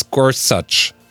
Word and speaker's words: Gorsuch: He said, "It's Gorsuch."